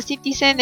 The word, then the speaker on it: NCTzen: Se pronuncia citizen en citizen porque significa como ciudadanos de la ciudad N.